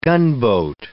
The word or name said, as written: gunboat